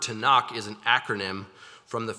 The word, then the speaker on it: Tanakh: So there would be vowels added when you say the word, so T-N-K pronounced would be Tanakh.